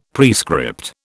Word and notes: prescript: British English

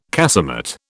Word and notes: casemate: British English

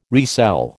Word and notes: resell: American English

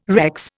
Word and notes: Rex: Indian

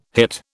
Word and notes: hit: British English

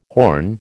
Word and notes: horn: American English

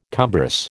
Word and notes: cumbrous: American English

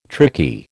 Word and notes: tricky: American English

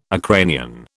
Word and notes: Ukrainian: British English

